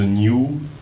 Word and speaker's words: Niiu: und über New. New. Sie, ja schreibt man wie? N-I-I-U. – Und das heißt eben so New, so jeder denkt so, das ist was Japanisches.